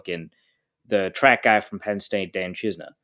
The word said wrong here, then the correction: Chisena: or Chisena, I guess is, we've been told is how it's pronounced.